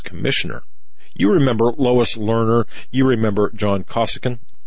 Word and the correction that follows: Koskinen: John Koskinen. Yeah, that's how you pronounce his name.